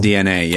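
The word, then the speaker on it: DeNA: Nintendo partnering with, is it DNA? Is it Denna? I think it's DNA, right? – That seems dumb. It's pronounced DNA, yeah, yeah.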